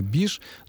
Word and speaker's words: биш: Ну, а вот что касается «биш», вот Валерий Анатольевич мне подсказывает, что это частица, собственно, русская, и что возникла она в разговорной речи из глагола «баиш», когда «баиш» произносится очень... Бегло, вот в таком аллегровом темпе речи, то вместо «баиш» получается «биш», ну, примерно так же, как и вместо «говорит» получается «грит», из «говорю» получается «грю», из «здравствуйте» получается «дрась».